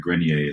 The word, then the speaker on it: Grenier: associated because it is French, like a premier cru. So Eric Grenier is how to pronounce my name.